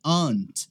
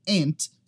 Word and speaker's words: aunt: Essa aqui é aunt, se você estiver no inglês britânico, ou ant, se você estiver com o inglês americano. É o mesmo som da palavra formiga, tá? Para quem está com o inglês americano. Ant. No inglês britânico, aunt. A gente não pronuncia aquele U ali. Você não vai falar aunt, nem aunt. Sempre aunt, tá?